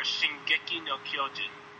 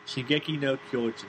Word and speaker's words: Shingeki no Kyojin: Or Shingeki no Kyojin. – Yes, that. Shingeki no Kyojin. Did I say that right? – Close enough.